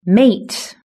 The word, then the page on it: mate: British English